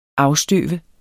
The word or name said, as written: afstøve